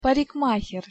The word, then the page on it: parikmaher: Russian